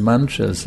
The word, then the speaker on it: mantra: This particular mantra, of course, there's thousands and millions of mantras. This word mantra is a Sanskrit word. It's becoming popular in English now, but mantra has two syllables.